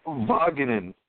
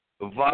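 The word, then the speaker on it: Van Wagenen: Yoannis Cespedes' agent's name for me, Brody Von Vaganen. Take the W and pronounce it like a V. Vaganen.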